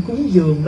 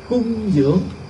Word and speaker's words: cung dưỡng: Thật sự chữ cúng dường đó là mình nói chứ đúng nghĩa là cung cấp dưỡng nuôi. Cho nên nếu tôi nói đọc đúng chữ là cung dưỡng chứ không phải cúng dường, nói cho đúng chữ là cung dưỡng.